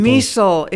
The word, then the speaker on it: Miesel: Miesel. It's like diesel with an M. M I E S E L. It's the German pronunciation, which trips people up, but I have clung to it faithfully for 54 years.